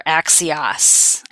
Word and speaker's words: Axios: Okay, so mine comes from actually not so much the web, but I subscribe to my local Axios newsletter, or Axios, I think is actually how they pronounce it.